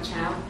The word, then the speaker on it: Chow: My name is Nam Chow. I'll spell that. N-G-O-C. Last name is C-H-O-W.